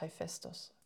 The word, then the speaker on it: Hephaestus: Und die neueste, boah, Hephaestus, glaube ich, spricht man es aus also das ist der griechische Name. Also, die, also auf, auf, Englisch haben sie es irgendwie ganz anders ausgesprochen, aber faktisch ist es Hephaestus.